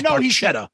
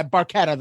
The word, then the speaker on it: Barchetta: So Red Barchetta. Am I saying that correctly? – Well, Getty says Barchetta. – Yeah, Barchetta, though.